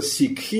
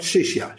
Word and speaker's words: сикхи: Он сказал, мы будем называться сикхи. Это такое англизированное, да, произношение. А они говорят шишья. Шишья – это ученик на санскрите.